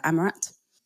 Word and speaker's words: Amerat: All of that stuff is my full name, Aliyah Amirat, and that's spelled A-A-H-L-I-Y-A-H, surname A-M-E-R-A-T.